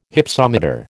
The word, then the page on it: Hypsometer: American English